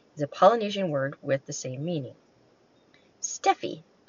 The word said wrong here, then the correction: Stepney: no, no, I said that wrong. Stepney, S-T-E-P-N-E-Y.